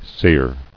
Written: Seared

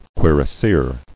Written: Cuirassier

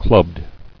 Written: Clubbed

